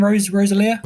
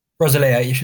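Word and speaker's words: Rosalía: Rosalia. – Yeah, Rosalia.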